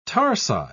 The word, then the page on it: tarsi: British English